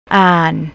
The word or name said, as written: arn